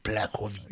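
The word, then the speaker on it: Błachowicz: Fantastic pronunciation, Blachowicz.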